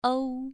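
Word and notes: u: tone 2

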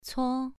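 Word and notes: cuo: tone 2

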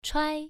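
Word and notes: chuai: tone 1

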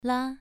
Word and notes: la: 2

